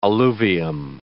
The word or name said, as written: alluvium